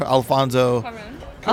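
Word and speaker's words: Cuarón: Curson, Curson, Alfonso. Cuaron.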